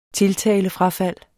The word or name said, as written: tiltalefrafald